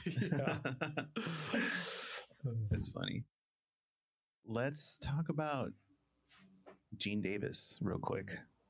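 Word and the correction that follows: Geena Davis: Am I saying her name wrong? – Gina Davis. – Yeah, Gina Davis.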